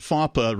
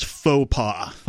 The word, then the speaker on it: faux pas: F-A-W. – F-A-W. – P-A. – PA. And so it was pronounced FAPA repeatedly, but it should have been pronounced like the significantly embarrassing blunder of mistake that yet another useless government alphabet agency actually is, which is faux pas.